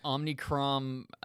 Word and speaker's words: Omicron: But the Omicron, did I say that right? – Sure.